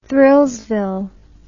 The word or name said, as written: thrillsville